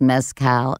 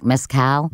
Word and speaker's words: mezcal: You went on, you know, a journey to find the best mezcal. I think I said it wrong. Mezcal? – Mezcal. – Mezcal. Mezcal. Yeah. Mezcal.